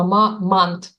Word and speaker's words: Мамант: Он действительно с ударением на первый слог, поэтому там не слышно, что он не мамонт, а мамант.